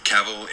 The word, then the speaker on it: Cavill: Cavill is pronounced like travel. Travel. Cavill.